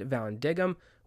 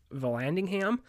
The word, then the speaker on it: Vallandigham: Now, I've actually also seen his name pronounced Vallandigham, but I've also heard it pronounced Van Diggum, so not sure exactly which one is right.